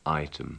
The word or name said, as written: item